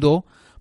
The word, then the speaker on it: dhow: que se llama do Se escribe D-H-O-W,